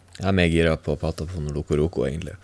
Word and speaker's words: Patapon: Men i hvert fall så kommer det Parappa the Rapper, og så kommer Patapon. Er det riktig uttalt? – Ja. – Patapon.